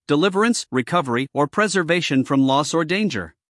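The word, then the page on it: deliverance: uk dɪˈlɪvərəns; en-us dɪˈlɪvərəns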